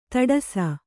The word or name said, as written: ಧಾಡಸೀ